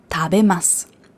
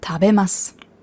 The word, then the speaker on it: tabemasu: Tabemas. Tabemas. Das heißt zwar tabemasu, aber das U wird ja nicht ausgesprochen, das verschluckt.